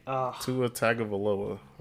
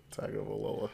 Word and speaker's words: Tua Tagovailoa: Tua Tagovailoa, right? That's how you say it? – Yep. Yep, that's it. – Tagovailoa.